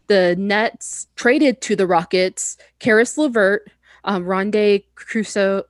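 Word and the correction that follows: Kurucs: Rodion's Krux.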